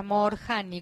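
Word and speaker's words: Moorjani: Anita Morshiva se llama. – Morshani. Se escribe Morjani. – O sea, Morjani, con doble O, Morjani.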